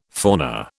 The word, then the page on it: fauna: British English